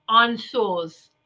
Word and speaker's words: Ansuz: And this one is, it's spelled A-N-S-U-Z, but it's pronounced Ansos.